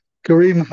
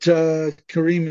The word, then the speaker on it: Karim: Karim Hamid. Karim Hamid. – is an Arabic name, though we don't say that very loudly these days for some reasons. But Karim is the pronunciation.